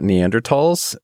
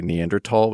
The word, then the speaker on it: Neanderthal: Dana and I are going to say Neanderthal, which is the correct pronunciation and the kind of Germanic pronunciation. – I tried to blur it just now. – And you're saying the sort of debased, Americanized version, Neanderthal, just so we're clear on that.